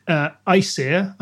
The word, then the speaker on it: Æsir: Very quickly, you've got your Æsir,